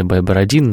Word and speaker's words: Байбородин: Мы долго выясняли, как правильно ставить ударение, Байбородин или Байбородин, но вот наш гость убедительно утверждал, что Байбородин, но суть не в этом.